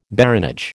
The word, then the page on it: baronage: American English